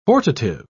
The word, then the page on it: hortative: British English